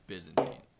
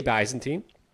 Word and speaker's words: Byzantine: We mispronounced it on the show, but not what we already know. – Byzantine? – Byzantine. It's Byzantine. – I say Byzantine. – Well, you'd be wrong.